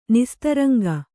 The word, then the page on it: ನಿಸ್ತರಂಗ: nistaraŋga; kannada